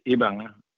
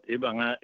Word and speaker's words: Ibanga: IB is a short for Ibanga, but Ibanga is spelled differently when it crosses the Atlantic Ocean. It's spelled, you know, it's pronounced differently, I would say. It's spelled the same. It's called Ibanga.